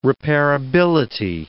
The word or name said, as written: repairability